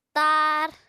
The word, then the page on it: taar: Apatani